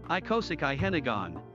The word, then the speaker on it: icosahedron: Icosahedron.